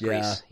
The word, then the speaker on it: Gris: It's pronounced Gris. – Oh, is it? – It's pronounced Greece.